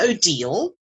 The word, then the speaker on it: Odille: I'm Odile. And to remember how to pronounce my name, if you think of deal or no deal, is the way to remember it.